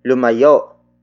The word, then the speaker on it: lumayo: Word for word it's lumayo mula sa atin ang malas.